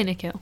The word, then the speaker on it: Nikhil: How would you pronounce that? Nikhil? – I'd say Nikhil.